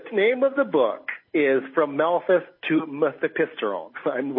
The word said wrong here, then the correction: Mifepristone: which I now mispronounced, Mifepristone, which is an abortifacient pharmaceutical.